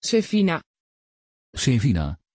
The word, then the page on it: Sevina: Dutch